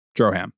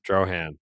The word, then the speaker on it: Drohan: You got to call him B-O-H. You sound like a casual. – Would you? – Yeah. Have you heard it pronounced Drohan or is it drone? I've been saying drone in my head. – No, the other way. Drohan. – Drohan. Like Zohan.